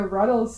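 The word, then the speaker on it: Rutles: Okay, number 9 on our list, we have the Ruddles soundtrack. – Oh, okay. So. – Yeah? Is that pronounced correctly? – Oh, yeah, absolutely right.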